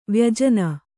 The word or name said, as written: ವ್ಯಜನ